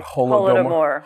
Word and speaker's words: Holodomor: So can you tell us the definition and the meaning of the term, I don't know how to pronounce it, Holodomor.